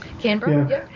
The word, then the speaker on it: Canberra: I'm going to pronounce it wrong. Canberra? – Canberra, yeah.